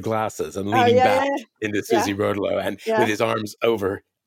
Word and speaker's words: Rotolo: Anyway, well, I'm going to say my agent was her agent on that book, and she pronounces it Rotolo. So. – Oh, great. Well, then it's Rotolo. It's Rotolo from now on. It's definitely Rotolo.